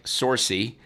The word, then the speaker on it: Sorci: S O R C I. Rhymes with horsey.